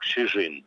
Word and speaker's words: Шижин: Я до сих пор выучил его имя, оно произносится как Шижин.